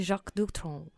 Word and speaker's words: Jacques Dutronc: Without further ado, Jacques Dutronc.